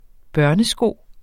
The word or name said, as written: børnesko